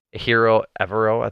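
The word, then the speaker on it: Ejiro Evero: Hiro Evero, I think is how you pronounce it. – Yes.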